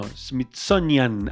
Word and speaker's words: Smithsonian: Smithsonian, Channel se llama, Smithsonian, qué palabra difícil para pronunciar en inglés, Smithsonian, no, Smithsonian, ahí está, Smithsonian Channel.